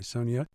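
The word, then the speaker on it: Sonja: Sonia with a J, but pronounced like a Y. Sonia, played by Claire Danes.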